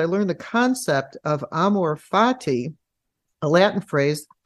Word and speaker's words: amor fati: and it's not and i don't know if i'm going to pronounce this correctly the latin phrase amor fati uh, um, perfect that, okay yeah.